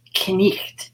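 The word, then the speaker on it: knight: Yeah, I think my favorite Middle English word is, now it's night, but then it's pronounced knicht. And so you pronounce every letter in it.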